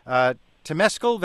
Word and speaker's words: Temescal: Jim's with us from Temescal Valley. Am I pronouncing that right? – You are pronouncing it correctly, yes.